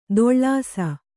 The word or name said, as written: ದೊಳ್ಳಾಸ